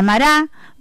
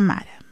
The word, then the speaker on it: Mara: lugar se llama Mara. Mire, tiene el acento en la en esta Biblia, ¿no? En la otra no tiene.